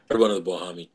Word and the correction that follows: Bomani: This Bomani.